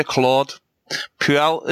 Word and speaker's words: Claude Puel: Yeah, staying with yourself, Richie, I'd just like to ask you about the manager, Claude Puel.